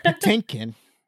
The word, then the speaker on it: Patinkin: Oh, shoot, I messed that name up. Patinkin, that's how you say it.